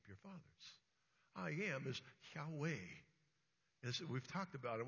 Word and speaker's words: Yahweh: You pronounce it correctly by breathing in and then breathing out. Yahweh.